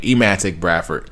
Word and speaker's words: Emantic Bradford: even if it was Semantic Bradford, mispronounced his name, Emantic Bradford.